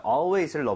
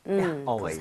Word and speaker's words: always: Always가 아니라 AL은 항상 R에 가깝게 입 모양 벌리셔야 됩니다.